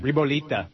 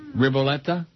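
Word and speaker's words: ribollita: Ribolita. Bread thick in Tuscan soup. – Riboletta? – Yeah.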